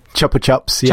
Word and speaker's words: Chupa Chups: But I do know Zool had Chupa Chupas. – Chupa Chups, Chupa Chups. See, I don't even know how to say it.